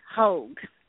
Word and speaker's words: Hogg: Kalui Airport, Maui, was designated as OGG in honor of aviation legend and Lihu native Captain Bertram J. Hogg, pronounced Hoag. Gay?